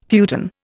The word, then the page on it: futon: Indian